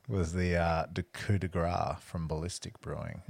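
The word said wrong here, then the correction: coup de grâce: Did I say that right, Weggy? – Wow. – Isn't that Coupe de Grace? – Coupe de Grace.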